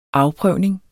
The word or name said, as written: afprøvning